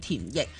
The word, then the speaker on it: 田奕: 咁佢个名咧就叫做田奕，奕咧系四个火字加埋一齐嘅嗰个奕字啦。咁广东话就可以读成系液体个奕啦，咁样。